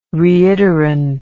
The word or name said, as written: reiterant